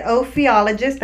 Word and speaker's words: ophiologist: So and they get ophiologist. That's how you say it. Ophiologist is a snake expert.